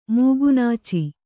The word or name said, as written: ಮೂಗುನಾಚಿ